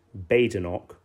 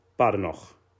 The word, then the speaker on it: Badenoch: Is it either an anglicised Badenoch or a more authentic guttural Scottish Badenoch?